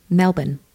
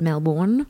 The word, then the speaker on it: Melbourne: Like really cool Americans even drop their accent to say it, so they don't even say Melbourne. They go like, Melbourne? – Yeah. Some people have told us to do that. And then we started doing that, and we're just like, don't say it like that. Say it Melbourne.